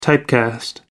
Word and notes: typecast: American English

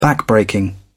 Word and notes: backbreaking: British English

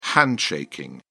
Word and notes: handshaking: British English

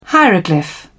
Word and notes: hieroglyph: British English

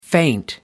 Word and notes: feint: American English

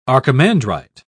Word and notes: archimandrite: British English

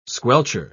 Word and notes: squelcher: British English

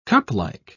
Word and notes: cuplike: British English

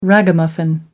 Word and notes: ragamuffin: American English

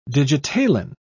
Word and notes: digitalin: British English

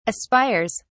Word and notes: aspires: British English

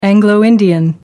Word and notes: anglo-indian: American English

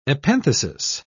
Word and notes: epenthesis: British English

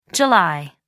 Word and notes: jul: American English

